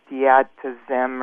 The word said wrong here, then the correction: Diltiazem: or something. – Diltiazem. – Yeah, okay, that sounds good.